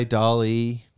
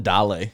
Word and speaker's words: DALL-E: So the new AI, DALL-E. What do you call it? – I call it DALL-E because I want to sound like...